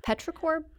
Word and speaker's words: petrichor: And then, and this is another one I may need a little help with the pronunciation, petrichor. – Petrichor Brewing, you did it.